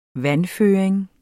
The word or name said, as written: vandføring